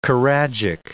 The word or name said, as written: choragic